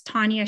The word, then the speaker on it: Tanya: It's Tanya Shears. So Tanya spelled with T-A-N-Y-A.